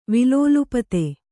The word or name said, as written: ವಿಲೋಲುಪತೆ